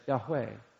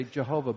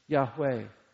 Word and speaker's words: Yahweh: That I am is what we in Hebrew would say Yahweh. Now some would say Jehovah, but probably the better pronunciation is Yahweh.